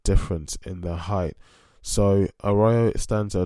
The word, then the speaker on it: Arroyo: Arroyo, A-R-R-O-Y-O. It's a Brazilian Portuguese surname, so I'm going to go with Arroyo as that's how it was pronounced and by one of the commentators during the fight.